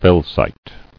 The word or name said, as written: Felsite